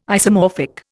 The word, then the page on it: isomorphic: Indian